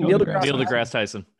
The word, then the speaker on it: Neil deGrasse Tyson: Neil deGrasse Tyson.